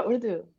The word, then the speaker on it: Urdu: And your play has large chunks of it in Urdu. Am I saying it right? – Yeah, Urdu.